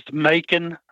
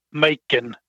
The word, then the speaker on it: Majken: Her name is spelled M-A-J-K-E-N. Her mother was Norwegian, and in her country, her name would, the K would be silent, and the J would be like an I sound, like Macon. But here, we pronounce it where the J is silent, and so it's Macon, the way I pronounce it.